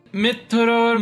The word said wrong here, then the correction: mrityor: No, it is Mrityor Mukshya. Mrityor. Yes. Mrityor.